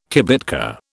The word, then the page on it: kibitka: British English